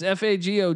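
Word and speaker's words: Fagot: It's F A G O T. Look, I don't know what to say here. – Fago. – Is the T silent? – Silent T. Let's just go with the silent T.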